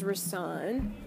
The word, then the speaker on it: Risaun: My name is Risaun. Risaun, that's how it's pronounced, for those of you who may have been wondering.